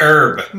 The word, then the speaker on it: herb: It's pronounced Herb. – Mystery. Mystery. Not in French, it's not. – Oh, yeah. I forgot that you pronounce it. I forgot you pronounce it Herb because you're British.